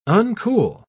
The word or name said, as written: uncool